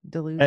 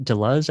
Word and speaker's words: Deleuze: Deleuze and Guattari? – It's pronounced Deleuze, I think, but Deleuze.